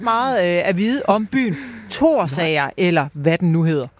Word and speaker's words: Thorsager: Thorsager.